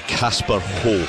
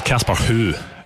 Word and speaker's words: Casper: I think Celtic will win the League Cup, Rangers will win the Scottish Cup, and the player of the year will be Casper Ho. Or Casper Who? Is it Ho or Who? – It's Casper Who is how it's pronounced.